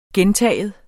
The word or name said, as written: gentagen